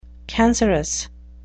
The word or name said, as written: cancerous